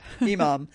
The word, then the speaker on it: imam: Imam.